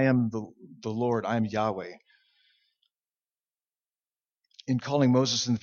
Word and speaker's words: Yahweh: And we said, we learned earlier that his name, his personal name, we think it's pronounced Yahweh.